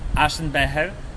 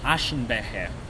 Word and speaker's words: Aschenbecher: Because Aschenbecher, that's how you would say in the dialect the ashtray, but in German it's Aschenbecher, so it's a little bit different, you know, like it's the same word, you would write it the same way, but you would just pronounce it with, you know, like Polish accent on German word.